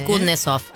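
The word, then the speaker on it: Kusnetzoff: Vuelve, a ver si me sale, Kutnesov. – PH, correcto, sí, Telefe. Andy Kutnesov, así se puede pronunciar.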